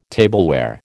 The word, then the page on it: tableware: American English